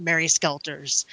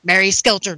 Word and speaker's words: Mary Skelter: welcome back to RPG Backtrack, where we end up getting into the round table, our personal feelings about Mary Skelter's. Mary Skelter's. Why did I say it like that? Mary Skelter nightmares.